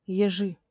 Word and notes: ежи: Russian

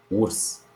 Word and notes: urs: [urs]; Romanian

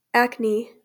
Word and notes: Acne: ˈækni; AK-nee

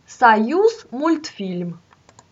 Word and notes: Союзмультфи́льм: səˌjʉsmʊlʲtˈfʲilʲm; Russian

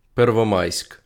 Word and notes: Первомайськ: [perwoˈmɑjsʲk]; Ukrainian

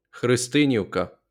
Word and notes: Христинівка: xrɪsˈtɪn⁽ʲ⁾iu̯kɐ

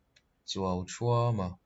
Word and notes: Chuauṭhuama: [t͡suʌu˩ .t͡ʂʰuʌm˥ .ʌ˧]; Mizo

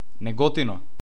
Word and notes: Неготино: nɛˈɡɔtinɔ; Macedonian

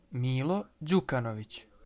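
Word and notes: Мило Ђукановић: Milo Đukanović; [mǐːlo dʑǔkanoʋitɕ]; Serbian

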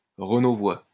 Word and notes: Renauvoid: ʁənovwa

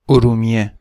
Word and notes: ارومیه: oɾumiˈje; Persian

